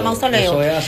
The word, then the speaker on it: mausoleo: Así es que se pronuncia mausoleo.